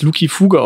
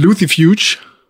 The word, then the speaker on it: Lucifuge: Und Luki Fuge ist Latein. Und deswegen müsste man es Luki Fuge aussprechen. Mein Lateinlehrer und der andere Lateinlehrer haben immer gesagt, C wird wie K ausgesprochen. – Ja, habe ich auch so gelernt. Kein Mensch sagt Caesar, sondern Caesar. – Also mhm. – äh, würde ich es gern weiterhin Englisch aussprechen.